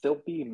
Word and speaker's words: Filpi: So you can find me at Twitter at Filpi Matt. My last name is spelled F-I-L-P-I.